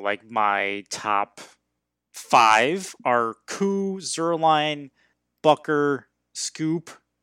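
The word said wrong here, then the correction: Succop: I never pronounced that right. Gold. – Ryan Suckup. – Suckup, thank you.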